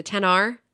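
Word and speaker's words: XR: the 10r right Mm-hmm. Forget that's how you say it. It's not X. It's 10.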